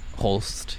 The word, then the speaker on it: Holst: Or how do you spell it? – H-O-L-S-T. – Holst. Yeah, you had it right.